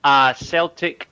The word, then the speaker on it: Celtic: まず、この Acsom なんですけど、これ、何の略かっていうと、 Acetic State of Mind ですね。で、僕、ちょっと発音があれなんで、正しい発音は、 Celtic State of Mind。